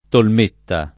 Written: Tolmeta

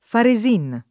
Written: Faresin

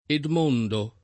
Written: Edmondo

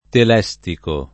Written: telestico

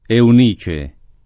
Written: Eunice

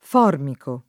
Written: formico

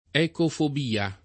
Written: ecofobia

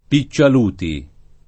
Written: Piccialuti

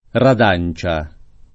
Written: redancia

